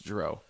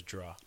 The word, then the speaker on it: drow: I've heard it pronounced drow. – Yes, that's what a lot of people say it as, but technically the pronunciation is draw.